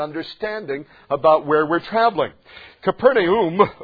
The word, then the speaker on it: Capernaum: And actually, in the Greek, that is pronounced Capernaum.